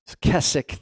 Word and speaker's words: Keswick: It's spelt Keswick. It's Keswick theology.